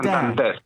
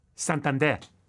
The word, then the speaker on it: Santander: Santander. – Okay. – So it's with the strong syllables at the end of the word.